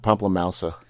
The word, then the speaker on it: pamplemousse: You pronounce the name LaCroix, so not French, because it's the family surname, but then you pronounce Pomplemousse, you pronounce it French. – Yeah, you're so not really, like Pamplemousse.